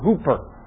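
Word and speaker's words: hooper (ὑπέρ): a Greek prefix that's pronounced "ooper" or "hooper." Okay? It's pronounced "hooper," from which we get the English prefix "hyper," right?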